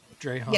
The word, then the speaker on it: Drahoon: Is it Count Drahoon or Count? – Yeah. – Drahoon. – Yeah, Drahoon. – Drahoon. – Yeah. Rhymes with the word so dragoon.